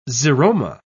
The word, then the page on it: xeroma: British English